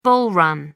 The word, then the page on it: bull run: American English